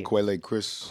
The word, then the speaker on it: Quelle Chris: Brown on the Wheels of Steels, and Overdose, Polyester, The Saint, Kid Named Breezy, Quell Chris, is that how you say it? – Quelle. Yeah, Quelle Chris.